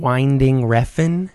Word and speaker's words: Winding Refn: Winding Refn.